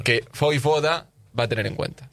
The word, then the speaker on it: Vojvoda: Vojvoda. – Vojvoda. – Vojvoda. Vojvoda. – Que no. – Sí, sí, porque la J se pronuncia ahí.